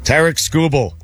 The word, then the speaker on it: Tarek Skubal: Tarek Skubal.